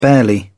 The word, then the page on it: barely: British English